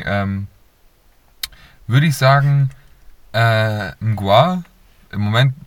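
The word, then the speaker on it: Mgła: MG und dann so ein komisches L, das durchgestrichen ist, A. Ähm, Mgua wird das ausgesprochen, bin ich mir ziemlich sicher.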